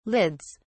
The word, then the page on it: lids: British English